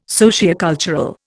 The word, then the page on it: sociocultural: Indian